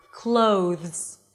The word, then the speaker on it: clothes: So it's not clothes, all right? You don't want to pronounce it just like a o sound. It's clothes. Think like you're adding a little W at the end.